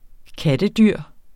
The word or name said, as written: kattedyr